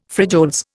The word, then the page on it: frijoles: Indian